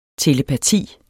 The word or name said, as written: telepati